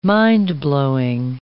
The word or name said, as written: mind-blowing